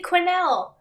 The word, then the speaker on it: Quesnel: He's in Quenelle.